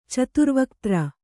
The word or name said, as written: ಚತುರ್ವಕ್ತ್ರ